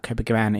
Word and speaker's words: Copacabana: It's the song Copacabana. Did I say it right? – Copacabana.